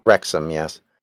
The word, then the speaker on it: Wrexham: If I'm pronouncing these wrong, but St. Asaph and Wrexham. – Wrexham, yes.